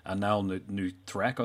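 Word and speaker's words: Anaal Nathrakh: Dave Hunt was locked into things with, and I always mispronounce that bloody band, Anal Nuthrak, I think it's pronounced. – Anal Nuthrak. – There you go.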